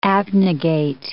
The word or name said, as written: abnegate